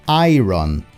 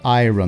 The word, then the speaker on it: iron: To, e aí vem uma coisa bem curiosa, porque é muito comum que o estudante de inglês fale em inglês iron, por causa da banda Iron Maiden, The Iron Man, só que a pronúncia correta é iron, iron, como se fosse A-I-A-R-N, iron.